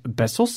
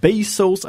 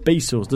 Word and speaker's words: Bezos: Wij zeggen altijd Besos, zoals de Spaanse zoentjes. Jij zegt altijd Besos. uh, eh, mea culpa, dat klopt. Maar de correcte uitspraak zou zijn eh, Bezos. En dat werd geïllustreerd in een filmpje waarin hij zijn eigen naam uitspreekt. En het is dus oh. Bezos.